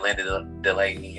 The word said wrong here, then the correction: Linda Denley: and Linda Denley. Let's get her name right. Put some respect on it. Denley.